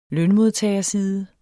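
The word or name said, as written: lønmodtagerside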